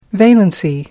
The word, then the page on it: valency: American English